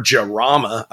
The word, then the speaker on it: Jarama: I mean, sure, everybody knows the Countach, but I have a Lamborghini Jarma over there, or Jarama, I believe is how it's pronounced.